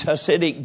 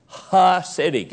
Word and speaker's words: Hasidic: You've heard me tell you about, even today over in Israel, you can see these Hasidic Jews. Hasidic, H-A-S-I-D-I-C, Hasidic Jews. When hillbillies use that silent H, you can't hear it. Hasidic Jews. Hasidic Jews. You got it? H-A-S-I-C.